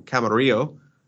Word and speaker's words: Camarillo: This comes from Kurt from Camarillo, and I said that correctly, so I don't want to hear anybody give me shit about it.